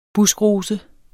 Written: buskrose

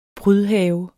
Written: prydhave

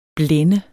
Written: blende

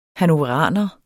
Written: hannoveraner

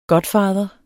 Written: godfather

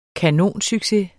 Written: kanonsucces

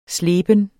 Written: sleben

